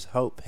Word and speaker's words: Hope: My name's Hope, H-O-P-E. And the only reason why I'm saying that is because a lot of people say Hulk. So, how would you even spell that? – Hulk. No, Hulk. When I say my name's Hope, they're like, what, Hulk? – Oh, I'm Hulk.